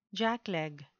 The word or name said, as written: jackleg